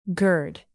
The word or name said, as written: gird